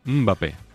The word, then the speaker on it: Mbappé: Y él me dijo que era una M, eh, claro, que es como es un sonido que en España no tenemos, que no es muda, la M es suave. Entonces, ¿cómo es? Sería algo así como Mbappé.